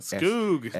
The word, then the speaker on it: Skoog: S-G-O-O. – Skoog. – S-K-O-O-G. You know, great poet. They're from around the same neighborhood. So maybe it's him, maybe it's not. – Yeah, it might be Skoog.